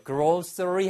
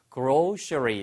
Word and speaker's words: grocery: 발음은 두 가지예요. grocery 하셔도 되고요. grocery 이렇게 하셔도 됩니다. 발음이 두 가지 가능합니다.